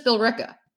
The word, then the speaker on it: Billerica: Sometimes it could be Billerica. Some people say it Billerica, but it's just Billerica.